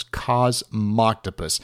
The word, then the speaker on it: Cosmoctopus: It took me forever to learn how to pronounce Cosmoctopus.